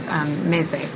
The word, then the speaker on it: meze: Well, maybe you can explain to me in your own words for people who are unfamiliar what the concept of meze is. Am I saying it right? – Yeah.